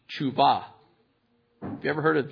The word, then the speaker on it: teshuva: It's spelled, it actually is looks like teshuva, but it's pronounced tshuva.